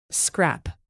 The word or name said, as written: scrap